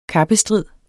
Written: kappestrid

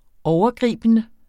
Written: overgribende

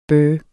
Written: bøh

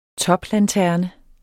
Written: toplanterne

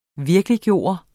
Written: VIRKELIGGJORTE

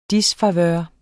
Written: disfavør

